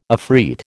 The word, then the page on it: afreet: American English